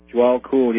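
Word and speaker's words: Djwhal Khul: or am I pronouncing this right, Jawal Kool? – Jawal Kool, yeah, Master DK, as he's called by others.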